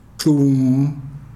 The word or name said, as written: xum